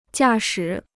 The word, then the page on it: 驾驶: jià shǐ